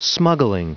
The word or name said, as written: smuggling